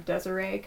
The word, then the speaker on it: Desiree: So on social media, I'm Desiree Cosplay.